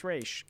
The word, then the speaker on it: Ra's: people say Ra's, and then, like, Talia, I think, is the one who says, it's actually pronounced Ra's. Common misconception.